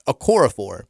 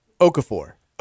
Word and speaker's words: Okorafor: Trevor cannot pronounce this young man's name. It's Okorafor. And he would sit there saying Okafor. And he could not do it because he could not pronounce it.